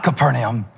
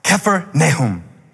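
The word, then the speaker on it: Kfar Nahum: He said, not Capernaum, Kephernahum. Took me seven times to say it until he was satisfied. He said, Kephernahum. Kephar, village. Nahum, comforter. Like the minor prophet, Nahum. He says, say it again. Kephar, Nahum. Stress on the K, Kephar, doesn't matter. What matters? What it means, village of the comforter.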